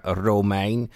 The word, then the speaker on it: Romijn: Actually, Rebecca Romijn, as we pronounce it properly in Dutch.